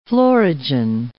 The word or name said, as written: florigen